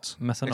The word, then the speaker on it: mecenat: Uttalas det så ens? Säger man mess, eh, messanat? – Mecenat. Exakt. Är det så?